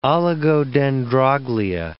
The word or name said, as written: oligodendroglia